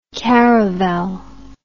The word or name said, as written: caravelle